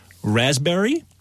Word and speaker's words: raspberry: K-N-E-E-L, Neil. – Raspberry, answer. – Silent right. letters. – Yes, silent letters. Oh, yeah. Raspberry is the silent S. – P. – Well, P. Sorry. – Silent P, right? Okay.